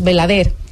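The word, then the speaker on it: Belladère: Belladere, dicen Belader, que es Belader ilegal, dicen los compañeros Belader, así se pronuncia.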